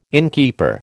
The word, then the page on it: innkeeper: American English